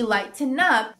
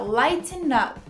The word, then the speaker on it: lighten up: Enfim, a pronúncia de lighten up, você pode falar tudo numa respirada só. Então fica lighten up.